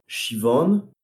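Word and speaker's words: Siobhan: Et ils ont une cousine qui s'appelle Shivan, mais ça s'écrit Sioban.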